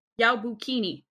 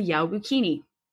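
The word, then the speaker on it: Yaobikuni: Another story tells of the Yaobukini. I said that wrong. Wow. Yaobukini. No, I didn't. Okay, never mind. Another story tells of the Yaobukini, or the story of the 800-year-old nun.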